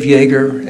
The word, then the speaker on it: Jaeger: My name is Dave Yeager, and I live in Shelbyville. Last name is spelled J-A-E-G-E-R.